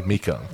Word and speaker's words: Mecum: Just so I don't seem ridiculous, this last name, is spelled M-E-C-U-M. – That's definitely Mika.